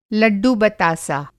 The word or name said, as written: लड्डू बतासा